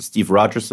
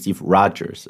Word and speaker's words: Rogers: Und, und und eigentlich sagt man auch nicht Steve Rogers, sondern Steve Rogers. Also das O spricht man etwas anders aus.